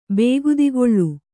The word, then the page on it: ಬೇಗುದಿಗೊಳ್: bēgudigoḷ